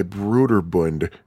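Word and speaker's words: Brøderbund: And I'm using Phil the No Swear Gamer's pronunciation here. The game was released by Bruderbund in 1982. Apparently that's how you pronounce the O slash that's in the name.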